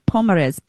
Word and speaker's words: Pomares: So this is the Quinta Nova Donosa Carmo Pompares. Okay, I'm going to say that again because my pronunciation, as you know, the Quinta Nova Carmo Pomares. Sorry, Pomares. P-O-M-A-R-E-S. $22.